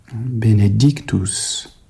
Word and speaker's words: benedictus: Donc ici, il ne faut pas trop souligner cette deuxième syllabe, et puis il faut réserver davantage l'intensité sur l'accent, benedictus, c'est-à-dire la troisième syllabe du mot.